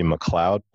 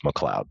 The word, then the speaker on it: McLeod: It is actually McLeod, but okay. Phonetically it looks like McLeod. So it is McLeod, though.